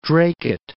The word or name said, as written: Dracut